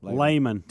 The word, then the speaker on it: Lehman: Lehman.